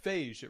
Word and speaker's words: Feige: I hear all the Feige. It rhymes with beige.